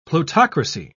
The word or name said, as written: plutocracy